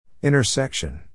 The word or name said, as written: intersection